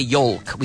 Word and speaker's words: yolk: You have inside the egg, you have the yolk, Y-O-L-K. So the L is silent. We don't say yolk. We say yolk. Yolk. Y-O-K-E is also pronounced yolk, but that is yugo.